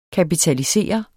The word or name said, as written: kapitalisere